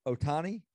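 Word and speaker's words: Ohtani: Otani? – That's it.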